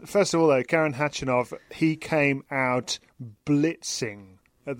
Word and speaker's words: Khachanov: Catherine, first of all, we're going to hear, as I said, from Andy Murray talking to you after his victory over Karen Khachanov a little bit later in this show.